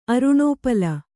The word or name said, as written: ಅರುಣೋಪಲ